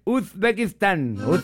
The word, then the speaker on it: Uzbekistán: Y la palabra Uzbekistán se pronuncia con W. – No. – Búscalo en internet para que vean. – No, está ahí, loco. Uzbekistán, ¿qué W? ¿Dónde diablo hay W ahí? – ¡Ay, papá! – No, está ahí mal. Es Uzbekistán. Uzbekistán. Acuérdate que ellos tienen un acentillo. Uzbekistano, Uzbekistán. Acuérdense que la Z es z. La Z tiene que pronunciarla como Z.